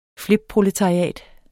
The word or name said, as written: flipproletariat